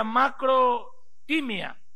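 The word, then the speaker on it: makrothymia: La paciencia es una palabra griega la cual se pronuncia macro-timia. Macro es con K, con K para macro y timia con H intercalada.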